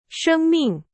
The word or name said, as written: 生命